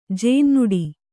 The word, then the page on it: ಜೇನ್ನುಡಿ: jēnnuḍi